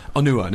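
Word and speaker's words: Onuoha: Obviously, I suppose, in case anyone hasn't heard what has happened, Nedim, is it, how do you say, is it Onua? – Onua.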